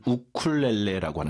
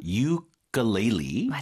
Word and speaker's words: ukulele: 우리는 우쿨렐레라고 하는 것 같은데. 네, 아하. – 유클렐리. – 맞아요. – 이렇게 발음하시면 됩니다. 우리말로는 이제 우쿨렐레라고 발음하는 것 같은데.